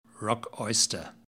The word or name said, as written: rock oyster